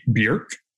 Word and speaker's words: Bjerk: So, right off the bat, I will say that tiny offer, that is actually a branded product slash idea slash course from Allie Bjerk. That is how you say her name, B-J-E-R-K.